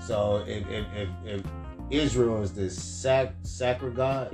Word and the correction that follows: synagogue: yes, sack of God. That's a place. – See? Synagogue. – Synagogue. Yeah. I'm saying it wrong.